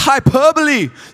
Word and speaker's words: hyperbole: And she says, Liam, it's pronounced hyperbole. Sit down, right? He said the word wrong.